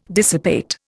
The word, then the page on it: dissipate: Indian